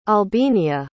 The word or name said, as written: albinia